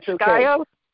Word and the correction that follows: Scio: No, it's Scio. Scio. – I would never S-C-I-O.